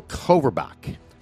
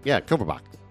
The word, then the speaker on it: Kovarbasich: All right, today I got a guy by the name of Daniel Koverbach. – Koverbach. – Yeah, that's close enough. Fuck it. – Koverbach. – Yeah. – Something like that. – It's spelled K-O-V-A-R-B-A-S-I-C-H.